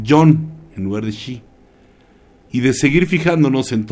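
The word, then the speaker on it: Shi (四): ya que en su pronunciación original es Shi, que también significa muerte.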